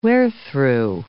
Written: wherethrough

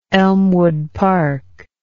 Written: Elmwood Park

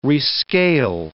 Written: rescale

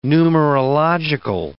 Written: numerological

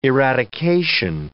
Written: eradication

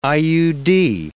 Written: IUD

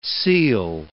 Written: seal

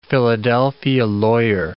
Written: Philadelphia lawyer

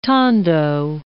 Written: tondo

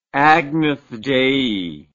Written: Agnus Dei

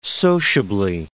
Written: sociably

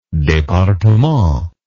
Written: departement

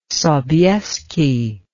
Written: Sobieski

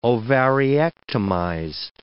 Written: ovariectomized

